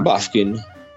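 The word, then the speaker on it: Bufkin: Sì, Buffkin.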